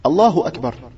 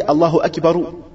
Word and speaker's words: Allahu Akbar: Mais c'est là que tu veux dire Allah il est grand, Allahu Akbar. Là tu le prononces. Là on n'a pas dit Allahu Akbaru.